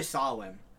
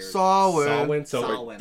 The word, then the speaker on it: Samhain: So it's actually Samhain, which I thought was really weird. – Samhain. – That's how it's pronounced? – That's how it's pronounced, yeah. – Samhain. – How the fuck do you get... Okay, it's Samhain, right? – They said that it was... It's an... It comes from Irish.